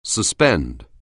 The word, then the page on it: suspend: anglais